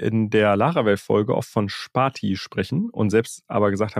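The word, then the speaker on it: Spatie: Ich war auf deren Website und die sagen selbst, Sparti is Dutch for space. The character between two words, you pronounce it Sparti. Also es heißt Sparti.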